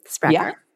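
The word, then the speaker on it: Sprakkar: Your book is called Secrets of the Sprakar. Did I pronounce that right? – Sprakar. Yeah.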